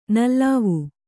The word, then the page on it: ನಲಾವು: nalāvu